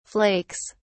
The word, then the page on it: flakes: British English